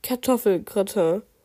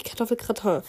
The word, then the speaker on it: Kartoffelgratin: dann gibt es meistens Essen, meistens gibt es Kartoffelgratin, sprich Kartoffelgratin. Achso, sie hat Kartoffelgratin geschrieben, sprich Kartoffelgratin.